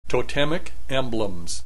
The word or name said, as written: totemic emblems